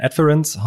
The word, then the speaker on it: Adference: Äh, danke, du hast alles richtig ausgesprochen. Zum einen mein Nachnamen äh, und auch Adference. Da haben wir schon die verrücktesten Kombinationen gehört, aber Adference ist richtig.